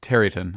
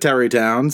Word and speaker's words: Tarrytown: I think it's actually pronounced Tarryton. I think it's one of those things. – Is it? Okay. – Yeah. That's not like travel town here. – Bing Crosby says it Tarrytown, so I'll leave it to him.